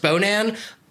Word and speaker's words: Bonin: Also, now that we know that his name is pronounced Bonan, I would like to propose Bonan the Carbarian.